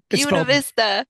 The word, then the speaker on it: Buena Vista: Buena Vista. – It's spelled Buena, but you pronounce it Buena.